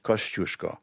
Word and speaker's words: Kościuszko: They don't write the accent on the first S because it's a sh, Kościuszko. But Zdzisław is a...